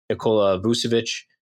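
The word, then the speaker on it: Nikola Vučević: Obviously they just had, Nikola Vucevic.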